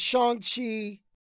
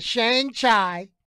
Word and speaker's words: Shang-Chi: I was making the long A. I was like being real American with it. But the correct pronunciation, according to Marvel now, is Shang Chi. Shang Chi. Not Shang Chai, but Shang Chi.